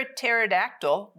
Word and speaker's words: pterodactyl: Why can't you hear a pterodactyl go to the bathroom? You're like, what? Why can't you hear a pterodactyl go to the bathroom? Because the P is silent.